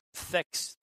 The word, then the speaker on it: THX: Thex 1138, or THX 1138. It's actually, like, Thex is how they pronounce it. They just throw the letters together and sound it out, right?